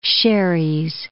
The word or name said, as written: Xeres